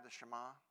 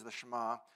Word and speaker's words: Shema: Is that, am I saying that right? The Shema. So the Shema.